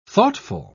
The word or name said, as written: thoughtful